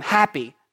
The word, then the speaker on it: Hapi: He's the God happy, not H-A-P-P-Y, but we would spell it H-A-P-I.